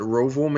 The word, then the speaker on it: Roval: So it is going to be unpredictable because, you know, a couple of years ago, the Charlotte Roval made its debut. And yes, I'm pronouncing that correctly. It's the Road Oval.